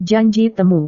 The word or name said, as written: janji temu